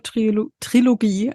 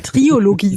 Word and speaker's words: Trilogie: eine queere Trilogie, ein Wort, das ich immer falsch ausspreche, möchte ich immer Triologie sagen, eine queere Trilogie bilden sollen.